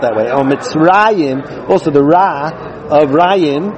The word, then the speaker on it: Mitzrayim: That's a Masorah that we have, that the proper pronunciation is Mitzrayim.